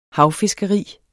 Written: havfiskeri

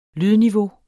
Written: lydniveau

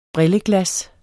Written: brilleglas

